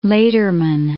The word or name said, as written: Lederman